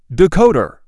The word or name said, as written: decoder